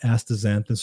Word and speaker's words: astaxanthin: So let's talk about astaxanthin.